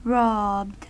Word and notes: robbed: American English

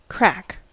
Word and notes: crack: American English

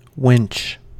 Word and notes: winch: American English

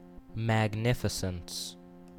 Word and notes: magnificence: American English

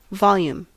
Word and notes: volume: American English

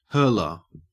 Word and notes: hurler: Australian English

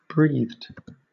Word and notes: breathed: British English

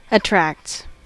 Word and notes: attracts: American English

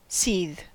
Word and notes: seethe: American English

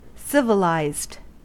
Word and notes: civilized: American English

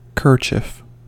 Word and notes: kerchief: American English

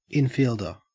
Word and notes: infielder: Australian English

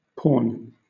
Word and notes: pawn: British English

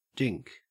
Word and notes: dink: Australian English

